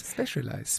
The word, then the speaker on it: Specialized: Wie spricht man es denn jetzt aus? – Specialized. – Specialized.